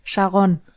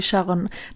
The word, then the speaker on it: Sharon: Ey, aber die spricht man aus, ganz wichtig, Sharon. – Ah, okay. – Tatsächlich spricht man die Sharon.